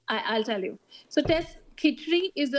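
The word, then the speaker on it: Khichdi: And is that when you created midlife, correct me if I'm saying this wrong, Kichdi? – Yes. – Okay, is it midlife Kichdi? – Yes.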